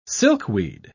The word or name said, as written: silkweed